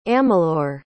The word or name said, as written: amalure